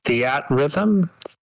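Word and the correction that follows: Theatrhythm: Theater Rhythm. – Theater Rhythm? – Yeah.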